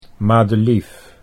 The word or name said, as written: Madelief